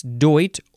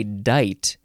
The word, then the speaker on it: doit: I think it's doit, or you can say dite. Now, it is spelled D-O-I-T or D-U-I-T.